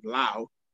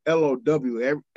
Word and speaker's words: Lowe: First of all, why is this dude's name Lau? It's spelled L O W L O W E. Everybody I've ever seen L O W E has been low, but right.